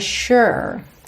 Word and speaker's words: sure: Atenção à pronúncia dessa palavra. O S tem esse som sh, sh, de SH, ok? Ou X. E o U não é aquele U fechadinho. É esse som meio ok? Sure.